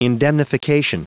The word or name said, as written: indemnification